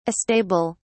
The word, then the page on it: astable: British English